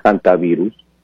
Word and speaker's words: hantavirus: Y bueno, no pronuncio bien el hantavirus, ¿es? – Sí, ajá, hantavirus en inglés. En español lo decimos, sí, hantavirus.